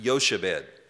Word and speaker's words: Jochebed: Her name is Jochebed. They probably would have pronounced it Yochebed.